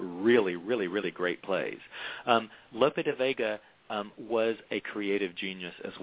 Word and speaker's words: Lope de Vega: You call Lope, how do I pronounce, Lope de Vega? – Yeah, Lope de Vega. Yeah, good.